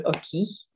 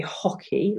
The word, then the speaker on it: hockey: You don't pronounce it, so it's not like in English. So the example I've got is le hockey. You don't say hockey like in English. There's no h, but you have to say le hockey.